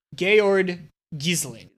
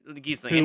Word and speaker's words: Georg Gyssling: Georg Gyssling. – Gyssling.